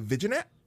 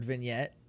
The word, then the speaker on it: vignette: Same problem that I had with a vignette. I would call it a viginette because I had no idea how to say the word.